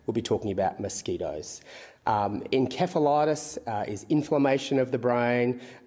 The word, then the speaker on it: encephalitis: We tend to go with the British pronunciation, which is encephalitis, as opposed to the American pronunciation.